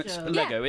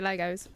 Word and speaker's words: Lego: They are officially Lego, just on its Lego. It's like sheep. – It comes from the Denmark word for let's play. – Yeah. – Lego. – It's Lego. It's Lego, but... Americans say Legos.